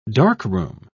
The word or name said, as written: darkroom